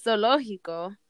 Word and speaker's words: zoológico: Um, for this one, the cool thing about the word zoológico is that it's pronounced zoológico, but it actually has the word zoo at the beginning. – So oh, it's oh, really? Z-O-O.